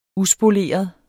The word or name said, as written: uspoleret